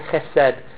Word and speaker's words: chesed: And the word for love here is that wonderful Hebrew word chesed. That word, if you're going to say it right, you have to say it from the back of your throat.